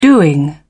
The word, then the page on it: doing: British English